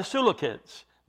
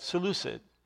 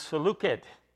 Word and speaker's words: Seleucid: I know some have heard, and you will hear it said Seleucid. The Greek is Seleucid. The C is pronounced like a K.